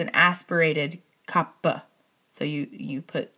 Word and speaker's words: kapha: And so I was reading a book the other day, and in the book, I finally learned, so I've mentioned this before, why I guess most everybody pronounces kapha, the kapha dosha, like kapha. And I guess I had heard somewhere that technically it's supposed to be pronounced kappa, like with an aspirated P, because it's K-A-P-H-A, because I learned this, finally I learned why. So in Sanskrit, there's no such F sound, and P-H does not make an F sound the way it does, like, in English and some other languages, but in English especially.